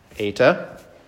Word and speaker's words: Eta: Eta, that's like your long E or even A, like as in late A sound. Eta.